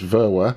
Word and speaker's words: Verwer: Oh, the correct pronunciation is just, it's exactly as it's spelled, which is just Verwer, but everyone mixes up the V and the W in the middle, and that's the most common mispronunciation.